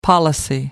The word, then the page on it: policy: American English